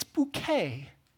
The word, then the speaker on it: Bucket: But there's a woman, she's like the main character of the show, basically, and her last name is spelled B-U-C-K-E-T. Anyone tell me what B-U-C-K-E-T is pronounced as? See, you said bucket, but what you don't know is it's bouquet.